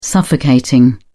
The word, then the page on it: suffocating: British English